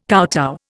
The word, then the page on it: kowtow: Indian